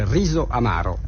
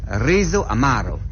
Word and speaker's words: Riso Amaro: Et en italien, on dit Riso Amaro. – Riso Amaro. Comme ça.